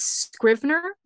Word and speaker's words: Scrivener: It's Scrivener, like scribe, like scriv.